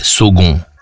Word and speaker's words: Saulgond: S-A-U? – S-A-U-L-G-O-N-D. – Ah ouais, Sogon.